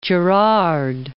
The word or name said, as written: Gerard